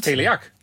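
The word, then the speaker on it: Teleac: Maar Teleak. Zegt het jou nog iets? Of zeg ik het goed? – Teleak.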